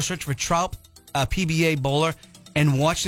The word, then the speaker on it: Traup: His last name is pronounced, it's spelled T-R-A-U-P, Trump.